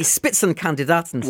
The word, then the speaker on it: Spitzenkandidaten: The Spitzenkandidaten.